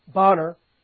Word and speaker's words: Bonar: Others like William Cowper, spelled Cowper, pronounced Cooper, much like Horatius Bonner, spelled Bonar, okay?